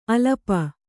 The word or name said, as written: ಅಲಪ